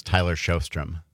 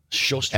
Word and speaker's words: Sjostrom: And for those who don't keep score at home, how do you pronounce that? – Well, my given Christian name is Tyler Sjostrom. – Sjostrom. – S-J-O-S-T-R-O-M.